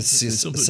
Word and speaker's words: Cecil Beaton: Cecil Beaton. – Cecil Beaton rediscovered it. You did the correct pronunciation, by the way. I gave it the American pronunciation.